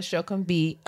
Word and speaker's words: Sokunbi: Is it Sokunbi? – Yes. – Sokunbi. – Sokunbi. I'm so sorry. I just want to make sure I say it right. But the very brilliant Bola Sokunbi of Clever Girl Finance to the kitchen table.